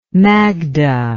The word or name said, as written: magda